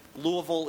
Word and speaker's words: Louisville: Professor Martin's work has received support from an impressive array of institutions: the Louisville Institute for the Study of American Religion — Louisville